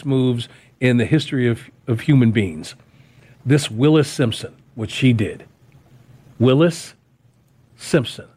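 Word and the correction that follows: Wallis Simpson: Or Wallace, Wallace Simpson, L-W-A-L-L-I-S.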